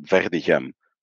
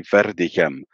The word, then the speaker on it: Verdegem: Like, I pronounce it Verdegem. I pronounce it with a French R. If you would say it with a rolling R, it'd be Verdegem. English-speaking people cannot pronounce that name.